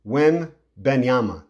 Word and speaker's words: Wembanyama: Victor Wembanyama. Hopefully I'm saying that right. Wembanyama.